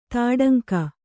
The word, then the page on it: ತೊಡಂಗ: Kannada